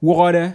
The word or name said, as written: Wuhawde